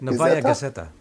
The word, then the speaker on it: Novaya Gazeta: Novaya Gazeta, yeah.